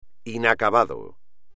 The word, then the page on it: inacabado: espagnol